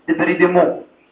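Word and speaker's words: débridement: ديبرايدمنت وات از ديبرايدمنت سكرابينج اوف ذا ابيثيليوم ويتش كونتينز ذا ايه ذا فيروس الحقيقه ان ديبرايدمنت دي لازم تتطبق كده لكن النطق الصحيح ديبريديمو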